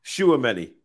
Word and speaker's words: Tchouaméni: Tchouaméni. Yeah, silent T.